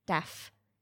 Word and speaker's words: Daff: Uh, he takes it, and can I get a pronunciation check on that, please? – Uh, Lady Daff. – Lady Daff. – Yeah, Daff. Like D A F F, but.